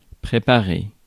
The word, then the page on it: préparer: [pʁe.pa.ʁe]